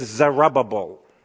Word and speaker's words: Zerubbabel: If you don't know how to pronounce that word, just say it's Zerubbabel, and then you got it.